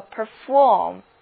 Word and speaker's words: perform: 注意重音在第二个音节，读作 perform。